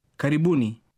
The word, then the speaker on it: Karibuni: Karibuni.